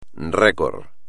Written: récord

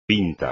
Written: pinto

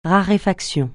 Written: raréfaction

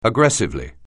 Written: aggressively

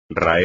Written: raer

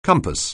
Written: compass

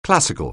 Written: classical

